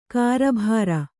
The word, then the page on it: ಕಾರಬಾರು: kārabāru